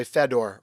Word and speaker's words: Fedor: If we were in Hungary, you would say Fedor, Robert. Fedor. But we're not in Hungary.